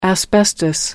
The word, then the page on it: asbestos: American English